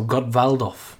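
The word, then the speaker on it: Gottwaldov: I'm going to pronounce the W as a V. So Gottvaldov in the 1970 Cup Winners' Cup.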